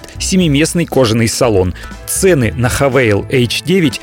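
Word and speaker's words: Haval: В кризис они запускают у нас продажи новой марки «Хавейл». Пишется как «Хавал», но они настаивают на произношении как «Хавейл».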